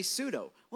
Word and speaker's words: pseudo: Like, why is there a P and an S when we never pronounce the P? We just say pseudo.